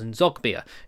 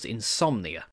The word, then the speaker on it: Nzogbia: Taylor arrived at St James's Park from Wigan, in part exchange for Charles Nzogbia, who'd made it clear that he wanted to leave. The final straw came after Kinnear mispronounced his name during an interview, in which he called him Charles Insomnia.